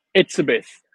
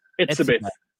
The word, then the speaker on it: Etsebeth: Etsebeth. Etsebeth.